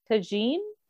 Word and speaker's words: tagine: Can I ask, where is chicken tagine? Is that how it's pronounced? – Yeah.